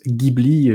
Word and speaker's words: Ghibli: car en italien, effectivement, du coup, ça se prononce Ghibli euh, avec un G dur.